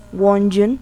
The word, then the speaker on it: Won-jun: Won-jun, sí, Won-jun, así se pronuncia, es como Jun.